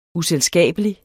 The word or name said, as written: uselskabelig